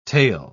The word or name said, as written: taille